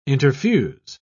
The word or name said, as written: interfuse